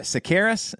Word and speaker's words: Tsakiris: Sakaris, is that how you say your last name? – That's perfect.